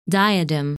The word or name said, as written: Diadem